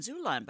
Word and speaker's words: Xulon: And Zulon, by the way, is spelled X-U-L-O-N. L-O-N. Oh, boy. X-U-L-O-N.